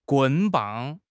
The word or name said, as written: 棍棒